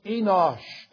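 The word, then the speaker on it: Enosh (אנוש): See, the Hebrew word for man in the first part of the question, what is man that thou art mindful of him, is pronounced Enosh, and it's also very often or sometimes used as a proper name in the Bible.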